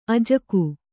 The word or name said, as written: ಅಜ್ಜುಗ